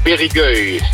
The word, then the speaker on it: Périgueux: Ja, je zit nu in het dorp Périgueux. Zeg ik het goed? – Ja, Périgueux. – Périgueux, ja. Oké.